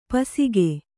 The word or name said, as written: ಪಾಸಿಕೆ